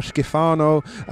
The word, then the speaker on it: Schifano: Mario Schifano. A small obsession of mine, these jerseys. – Is that a pronunciation, please? I think your pronunciation there was fine, Lionel.